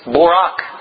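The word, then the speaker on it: borak (ברך): The Hebrew word is barak, pronounced borak.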